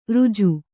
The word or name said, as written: ಋಜು